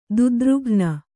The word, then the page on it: ದುದ್ರುಘ್ನ: dudrughna